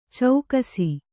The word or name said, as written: ಚೆಕ್ಕಿಸು